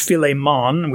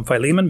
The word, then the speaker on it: Philemon: we would pronounce in English, Philemon, we would say in, in Greek if we want to do that. I'll just call him Philemon because that's how we're familiar with it. But he is mentioned here in verse 2 as the recipient of this letter.